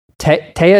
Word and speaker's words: Taya: I don't know if you remember this, Paul, but I said, I can only go watch the movie if Taya, is that her name? Am I saying it right? – Yeah, that's right.